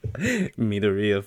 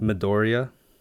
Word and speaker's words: Midoriya: Midoriya from My Hero. What? – That's how you say it? – That's how they say it. – I believe you because I watch it dubbed. – Midoriya? – Young Midoriya.